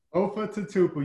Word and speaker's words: Lofa Tatupu: Did I pronounce that right? Lofa Tatupu? – Lofa Tatupu. You got it, man. You're probably one of the few that pronounced it right the first time.